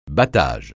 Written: battage

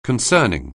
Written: concerning

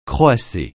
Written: croasser

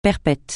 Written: perpète

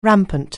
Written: rampant

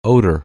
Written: odor

